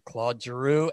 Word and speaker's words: Claude Giroux: And this week, we are so pleased and honored to have number 28, the captain of the Philadelphia Flyers, Mr. Claude Giroux.